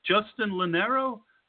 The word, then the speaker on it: Justin Lonero: Justin Lonero.